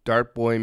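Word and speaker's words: Dartboi: is provided by, I don't know how to pronounce this properly, Dart Boy Music. Well, that was pretty easy, actually. It's D-A-R-T-B-O-I Music.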